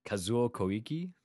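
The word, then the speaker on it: Kazuo Koike: Let's all forgive that pronunciation. – Yes. – Really? You can do better? – Kazuo Koiki. – Uh-huh, which is what I said. – And no, you didn't. You added some letters there.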